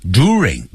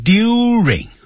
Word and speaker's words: during: During times of war， during 不要念成 during，